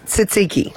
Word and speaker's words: tzatziki: How do you say it, though? – Tzatziki. Yeah. Tzatziki. Tzatziki.